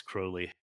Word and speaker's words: Crowley: Crowley, Crowley, right. How you say it. And yeah. I think Ozzy Osbourne screwed it up. It, it's Crowley.